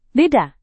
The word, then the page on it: beda: indonesian